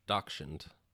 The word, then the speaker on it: Dachshund: He's, like, a dash hound crossed with, like, a chihuahua. And he's got people eyes and, like... – Dachshund. – Dash hound. Dachshund? – Dachshund. That's how you say it in German.